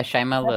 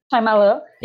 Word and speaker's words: Shyamalan: Sim, uh, eu, eu, tinha pensado em falar alguma coisa, é, eu não sei dire, direito pronunciar o nome dele, mas é Shyamalan? – É, Shyamalan. – É assim, Shyamalan.